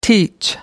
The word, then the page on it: teach: American English